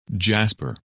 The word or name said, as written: Jasper